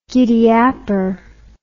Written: giddyapper

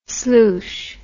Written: sloosh

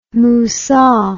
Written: musar

